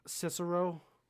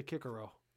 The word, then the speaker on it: Cicero: Well, it's also like Cicero. Is actually Kikero. Which sounds stupid to me. – It does sound stupid. – I hate it, but it's apparently the right pronunciation.